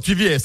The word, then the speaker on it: TVS: Hocam bu arada bir dinleyicimize de Soltek'ten e, bu nasıl okunuyor bilmiyorum ama TVS galiba. – Doğru TVS.